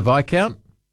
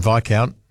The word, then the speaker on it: Viscount: Was my first experience with the silent S was the Viscount. What a weird-looking word that was.